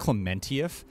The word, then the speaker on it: Klementieff: It's actually pronounced Klementieff.